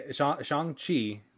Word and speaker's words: Shang-Chi: Is that how you pronounce it? Shang-Chi? – Yeah, Shang-Chi.